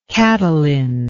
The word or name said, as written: catalin